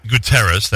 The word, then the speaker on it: Guterres: The UN, and you mentioned the United Nations earlier, Mr. Guterres, if that's how you pronounce it, the new Secretary General. – Guterres, thank you.